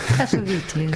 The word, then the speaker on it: Cassavetes: Azt mondta, hogy tudod, hogy hogy kell Cassavetes nevét kiejteni. Cassavetes. Cassavetes? Igen, én mindig Cassavetesnek mondtam. – Én is Cassavetesnek mondtam. – Így mondják, én igen, egy amerikai barátnőmtől hallottam. – Ja, kiejtette, és így kell.